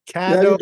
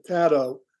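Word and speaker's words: Cadot: Cadeau. Yeah, you did a little tiny bit. So it's my parents pronounced it Cadeau, and I've always said it rhymes with shadow.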